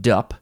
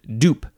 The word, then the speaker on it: DUP: So I guess you can't, you can't, You can't pronounce it dup. You have to pronounce it dupe because it's duplex and duplicate.